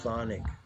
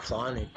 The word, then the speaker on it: chthonic: And so this idea of thonic, you know, the word C-H-T-H, that's a cool word, C-H-T-H-O-N-I-C, thonic, how do you say it? Thonic, the thonic gods emerged.